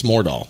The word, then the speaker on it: Mordal: Is it D-A-H-L? – It should, you know, I should put an H in there so that people pronounce it right. It sounds like there's an H in there, but there's not. It's Mordal.